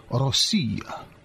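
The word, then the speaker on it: Rossiya (Россия): Say it correctly. Place the emphasis where it belongs. It's more like Rossiya.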